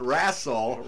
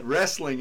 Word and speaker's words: wrestling: I'm a hick, so it comes out wrassle. But wrestling is the correct pronunciation.